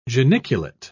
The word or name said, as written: geniculate